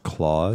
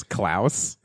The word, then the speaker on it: Klaus: I believe that's pronounced Claus. And there's one as in Santa Claus. Klaus.